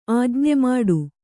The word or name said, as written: ಆಜ್ಞೆಮಾಡು